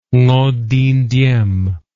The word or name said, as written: Ngo Dinh Diem